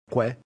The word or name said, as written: que